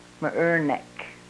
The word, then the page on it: mrrrnek: Yurok